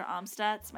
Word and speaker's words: Amstutz: All of my social media handles are at Sandra Almstutz.